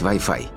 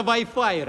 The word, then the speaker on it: Wi-Fi: На индикаторе подключения загорается надпись Wi-Fi. – Wi-Fi? Wi-Fi. А, что-то японское. Ну, или Wolfenstein. – Вообще-то произносится Wi-Fi, Ральф.